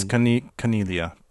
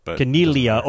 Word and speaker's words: Caniglia: I think it's Caniglia. – Caniglia. Oh, so yeah. It's silent G. – Caniglia. Like familia.